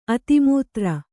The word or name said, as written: ಅತಿಮೂತ್ರ